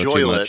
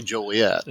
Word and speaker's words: Joliet: I think I was told that that's the way you're supposed to say Joliet. – Instead of Joliet, I used to go. – I've been saying it wrong.